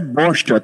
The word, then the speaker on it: bocha: Cuidado com a pronúncia, é bocha, tá? – Isso. É, B-O-C-H-A, bocha.